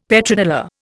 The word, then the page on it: Petrodollar: Indian